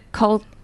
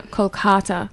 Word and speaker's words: Kolkata: Is it Kolkata? – Right. Yeah. – Kolkata.